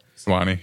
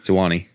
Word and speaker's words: Suwannee: Yeah, a, lot, a lot of my friends just came back from uh, Su- Suwannee. I, I, don't know if I'm saying that right, but Florida. Suwannee, yeah. Suwannee, yeah.